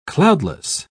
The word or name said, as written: cloudless